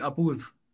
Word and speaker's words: Apurv: It's an Indian name. Hard time pronouncing it. Just call me Apurv.